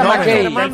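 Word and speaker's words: Mackay: Se pronuncia Macay.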